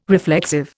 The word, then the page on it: reflexive: Indian